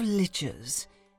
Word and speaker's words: liches: The story, A Cast of Liches. It says here, rhymes with witches.